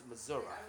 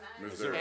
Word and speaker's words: Missouri: If you're from there, it's pronounced Missouri.